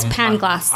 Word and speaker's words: Pangloss: So Candide is a student of a character in the book called Pangloss. Is that how you say it? – I don't know. – Yes, Pangloss.